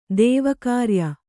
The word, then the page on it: ದೇವಕಾರ್ಯ: dēva kārya